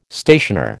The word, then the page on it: stationer: American English